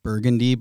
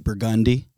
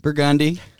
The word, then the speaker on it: Burgundy: Yeah, like some of the streets in New Orleans, it's like it looks like it's spelled Burgundy, but they say Burgundy. – Right. – It's Burgundy.